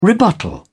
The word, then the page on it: rebuttal: British English